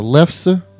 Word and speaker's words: lefse: And then, of course, you have the school bread, rice cream, the lefse. Is that how it's pronounced there? – That's how I say it.